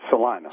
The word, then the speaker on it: Salina: Or is it Salina? Which is... – It's Salina. – Salina, okay. I'm doing the Spanish pronunciation.